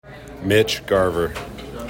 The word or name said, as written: Mitch Garver